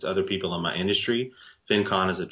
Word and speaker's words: FinCon: You created the FinCon. I don't know if I pronounced it correctly. – FinCon.